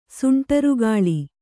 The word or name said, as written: ಸುಂಟರಗಾಳಿ